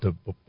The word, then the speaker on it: Die: Cremant D-I-E is spelled, mm-hmm. is pronounced D, fortunately.